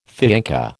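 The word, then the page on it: fiancé: American English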